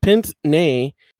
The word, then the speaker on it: pince-nez: And his pince nez that's spelled P I N C E N E Z. So the French word for nose.